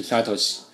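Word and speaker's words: 聡: Nakamoto 比较公认是表示中本二字，但这个 Satoshi 到底是代表什么汉字呢？日本媒体选择了哲史这两个字，中文媒体比较习惯用聪这个字，因为哲史和聪在日语里的发音都是 Satoshi。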